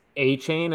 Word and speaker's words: Achane: I'm glad that it's Achane and now I know how to say it, but Achane is insane.